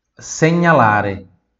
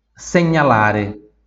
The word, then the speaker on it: segnalare: Próxima palavra, señalare señalare repita, señalare segnalare. Mais uma vez, segnalare.